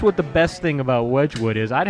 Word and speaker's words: Wedgwood: It's not like the word wedge. It's not the thin end of the wedge. No. It's Wedgwood. – It's actually pronounced Wedgiewood.